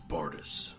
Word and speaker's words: Bardus: Well, if you mean me, my name is Bardus. – Okay. And how do you spell that? – B-A-R-D-U-S.